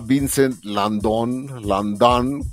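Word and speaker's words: Vincent Lindon: No, este año fue un actor que se llama Vincent Landon, como estoy pronunciándolo en francés.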